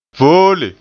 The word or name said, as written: voole